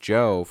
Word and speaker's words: Djo: And Joe, for those of you who don't know, it's spelled D-J-O. And it is the actor and musician. – That's correct.